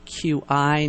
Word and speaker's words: qi: and I just want to say it's spelled Q-I, not C-H-I. It's Q-I.